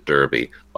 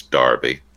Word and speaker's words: Derby: We apologize, we pronounced it Derby, like the hat or the Kentucky race, but it's Derby. – Derby, yeah. – Spelled with an E.